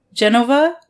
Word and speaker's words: Genova: In Genova? Is that how you say it? – Yes. Yes.